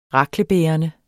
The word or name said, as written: RAKLE